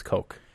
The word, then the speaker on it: Koch: Did we ever settle on how to pronounce? Is it Koch Media? Koch Media? – I think you said Koch. – Koch? – I think you told me it was Koch. – You did, yeah.